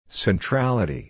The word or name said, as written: centrality